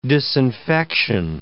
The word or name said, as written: disinfection